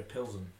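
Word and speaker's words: Plzeň: FC Victoria Plesen travelled to Roma. – Victoria Pilsen. Pilsen.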